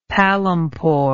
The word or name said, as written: palampore